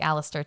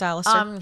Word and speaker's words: Aleister: It's A-L-E-I-S-T-R. It sounds like Alistair to me. – Yeah, I think that's Alistair.